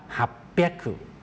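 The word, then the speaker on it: ฮับ เปีย กขู่ (八百): 800 ไม่ได้ออกเสียงว่าฮัจจิเฮียขู่เขามีการเปลี่ยนแปลงเสียงอ่านนะครับออกเสียงว่าฮับเปียกขู่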